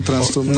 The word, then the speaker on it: Tranströmer: Tú lo pronuncias como edrújulo, así es la pronunciación, Tranströmer.